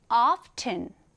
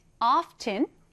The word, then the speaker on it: often: 자, 발음할 때는요, 두 가지 방법이 있습니다. 첫 번째는 이 t를 살려서 often. 두 번째는 t 발음을 생략한 채로 often이라고 하는데요. 여러분은 often, often 어떤 것이 더 마음에 드시나요? 사실은 두 번째, T를 생략한 채로 발음하는 것이 훨씬 더 흔히 사용되긴 합니다.